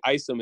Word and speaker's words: Isom: And if you would like to follow me on Twitter or Instagram, it's at Eli Isom, but the Isom is spelled EYESUM, because a lot of people have a hard time pronouncing my last name. So I had to spell it out as if it was, you know, how it's pronounced.